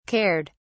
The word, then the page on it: cared: British English